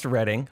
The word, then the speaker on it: Reading: So it's pronounced Reading. I learned that. R-E-A-D-I-N-G.